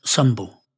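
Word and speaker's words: sumbel: The main rites celebrated in heathenry are called blot, pronounced blow, and symbol, pronounced sumbul.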